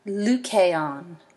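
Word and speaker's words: Lucaon (Λυκάων): Note the capital lambda that starts this word. Okay, it's Lucaon, Lucaon.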